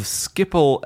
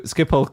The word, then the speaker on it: Schiphol: My fact is that every time the UK delays a decision on expanding Heathrow, the head of Schiphol Airport in the Netherlands sends Heathrow a congratulatory cake to thank them for all the extra custom.